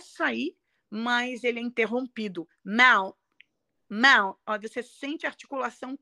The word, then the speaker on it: Mount: E não é Mon Vernon. – Não, é Mount.